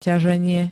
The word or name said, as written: ťaženie